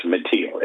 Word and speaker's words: Metele: And I guess it's pronounced Metele, M-E-T-E-L-E, Borno State.